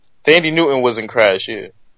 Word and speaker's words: Thandie: The H is silent, sir. But anyway, go ahead. – So, what is it? – Thandie Newton. – Thandie Newton. Oh, I always pronounce it as Thandie, but I mean, she can get it.